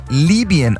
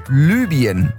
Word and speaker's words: Libyen: Libyen wird korrekt ungefähr Libyen ausgesprochen. Nicht Libyen, wie ich gerade eben.